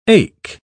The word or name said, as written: ache